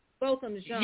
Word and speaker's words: Botham Jean: Botham Jean.